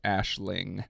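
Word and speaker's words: Aisling: A-S-H-L-E-Y. – Yes. Yeah. But it's spelled, like, I think it's Iceling. – It looks like Iceling on paper, and it's pronounced Ashling.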